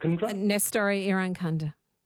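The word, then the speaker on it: Nestory Irankunda: Nestori Irankunda? – Yep. Nestori Irankunda.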